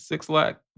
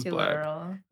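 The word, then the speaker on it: Blxst: Ayana says that it is 6 lakh because if he wanted his name to be pronounced black, he would have put a B there instead of a 6.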